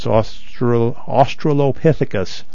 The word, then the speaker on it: Australopithecus: I even did pronounce Australopithecus right the first time.